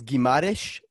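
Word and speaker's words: Guimarães: I think Bruno, I think it's Guimaraes is the correct way to pronounce it.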